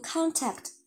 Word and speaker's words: contact: 其实它是读 contact。啊，OK，就是大部分的情况下，它 contact 比较多啊，就不论是英式、美式，大部分就是无论是它的名词还动词，我们可能都可以用它的 contact。